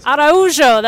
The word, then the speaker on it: Araujo: Hey, so I've just run into Danilo Alves Araujo. You got to help me with your last name. How do I say it in Portuguese? – Araujo. – Araujo. That's beautiful. Okay. And this is Portuguese.